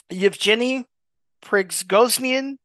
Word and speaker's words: Yevgeny Prigozhin: RAIFAM, which has been linked to Yevgeny Prizogin, who is an oligarch who is, and by the way, I'm sorry for mispronouncing. I think it's Yevgeny Prigozhin, who is an oligarch who is indicated by special counsel to Robert S.